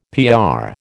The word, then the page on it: PR: American English